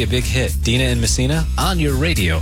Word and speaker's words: Messina: Is that how you pronounce it? Messina? – Here. – Yes. – You're Dina. – Yes, Dina and Messina.